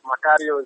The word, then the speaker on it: Macario: It's, it's, that twang is actually correct. Macario is the proper pronunciation of my name.